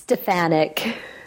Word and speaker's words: Stefanyk: Did, did I say it right, Anne? I'm always. It's Stefanyk. Stefanyk.